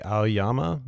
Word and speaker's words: Aoyama: But how do you say his last name? Oyama? – I would say Aoyama.